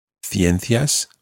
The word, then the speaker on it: ciencias: So if you look at the word, or if you imagine the word, ciencias, that would be spelled C-I-E-N-C-I-A-S. Ciencias. Ciencias would be sciences. In Latin America, it would be pronounced ciencias.